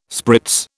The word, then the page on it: spritz: British English